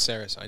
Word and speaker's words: Saracen: It's Saracen. I knew I said his name wrong.